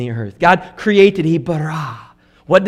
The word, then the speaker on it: bara: When you look at the word that is used, the Hebrew word for create used here is the word bara, B-A-R-A, bara. And you have to say it like that because it's a powerful word.